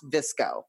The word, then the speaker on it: VSCO: So where the VSCO girl, nomenclature, the word VSCO comes from an app that stands for it's VCSO, and it's pronounced VSCO, like disco. – Okay. – But with a V.